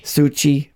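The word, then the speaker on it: Soucie: And another weird fact, Lola Bunny, originally played by, Kath Saucy or Saucy? – I think it's Suchi. – Suchi. Wow. Saucy is what it's spelled like. Wow. That. So she's Saucy. Kath Suchi? Okay, sure. You're right.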